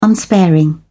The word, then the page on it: unsparing: British English